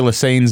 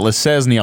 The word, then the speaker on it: Lesesne: We've seen a lot of people mispronouncing Troy Lesane's name. We get Lesesne all the time. So thank you for getting Lesane right.